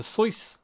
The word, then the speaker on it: Seuss: Yeah, Dr. Seuss. Did you know that that's his? That's how you pronounce his name. It's meant to rhyme with rejoice.